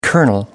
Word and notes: kernel: American English

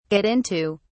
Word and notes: get into: British English

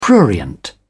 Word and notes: prurient: British English

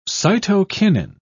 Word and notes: cytokinin: English; British English

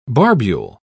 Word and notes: barbule: British English

